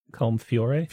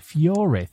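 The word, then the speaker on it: Colm Feore: Is it Colm Fiore? – Fiore, thank you.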